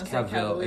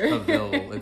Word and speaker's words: Cavill: Cavalier. I no, it's Cavill. It's Cavill. It's like Cavill or something.